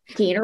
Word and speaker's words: Keener: You know, like Keener rhymes with Wiener, because that's my like work name, Beth Keener.